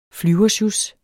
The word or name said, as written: flyversjus